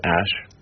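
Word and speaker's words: Æ: I mean, it's just X, letter X. The A-E is like pronounced Ash.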